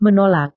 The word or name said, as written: menolak